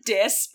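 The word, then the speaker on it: Dís: Dis. I know. I knew you were not going to let that name slide. It's pronounced like Dis, but I'm saying Dis.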